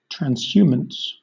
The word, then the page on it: transhumance: British English